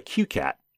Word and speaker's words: CueCat: For example, if you've ever wanted to be able to scan your own barcodes, there's this thing called a QCAT. That's spelled C-U-E-C-A-T.